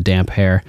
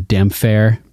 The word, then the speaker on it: Damphair: It's funny, in the audiobooks, Roy Dotrice mispronounces it. He calls him the Damphair. Damphair, because Damphair. – He misread the P and the H as a F sound instead of separating them as two, like, they would two hair, words, yeah, combined, damp hair.